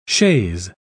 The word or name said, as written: chaise